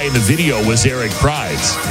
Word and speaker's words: Prydz: Is it Eric Prydz? – Yeah. It's Prydz. P-R-Y-D-S. I've heard it said both.